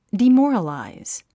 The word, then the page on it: demoralize: dimɔ’ːrəlàiz; ディモォラライズ